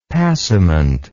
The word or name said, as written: passament